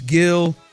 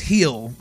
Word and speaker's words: Gil: Today, they're running Framber tomorrow against Luis Gil, who is or Luis Gil. I know that G is silent, or said as an H.